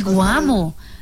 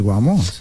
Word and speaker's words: Guamo: Entonces, ¿quién es Isabel Guamo? Se dice así. – Chao, Ana. – Sí, claro, el Guamo. Es un lugar muy bello de Colombia, nuestra geografía.